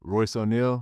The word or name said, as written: Royce O'Neale